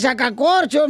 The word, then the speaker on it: sacacorchos: Se dice sacacorchos, mensa.